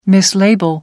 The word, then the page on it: mislabel: American English